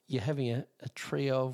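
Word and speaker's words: Treo: Trio, T-R, but it's spelled T-R-E-O, T-R-E-O.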